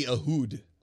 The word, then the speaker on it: Ehud: But it's E-H-U-D. – E-H-U-D. – Ahud. – Ahud. – Ahud. Ahud. – It wouldn't be Ahud.